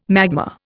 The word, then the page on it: magma: Indian